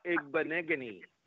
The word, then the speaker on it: Igbinoghene: So it's Noah Igbinoghene. – Igbinoghene. No, that's not that bad. – Igbinoghene.